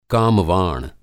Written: कामवाण

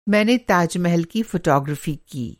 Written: फ़ोटोग्राफ़ी

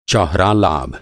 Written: चौहरा

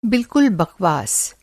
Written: बकवास

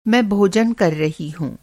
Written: भोजन

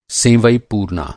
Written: सेंवई

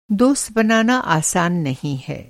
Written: दोस्त

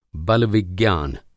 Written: बलविज्ञान

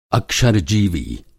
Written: अक्षर-जीवी

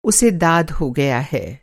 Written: दाद

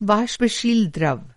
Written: वाष्प-शील

